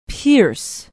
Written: pierce

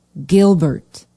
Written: Gilbert

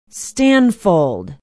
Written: stanfold

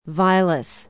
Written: vilas

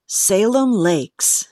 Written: Salem Lakes

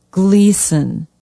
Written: gleason

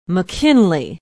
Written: mckinley

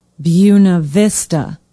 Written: buena vista

